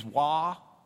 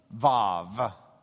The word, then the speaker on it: vav: The Vav is W-A-W. That's because it's a German W. The Germans pronounce W like we pronounce V. So it reads Wah, but it's vav.